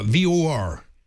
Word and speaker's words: VAR: noticed that on uh, Match of the Day he calls VAR VOR. – Mm. – Yeah, because that's the Irish pronunciation. – It is, yeah. So it does sound like VOR.